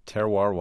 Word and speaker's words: Terroir: Terroir Wine Bar.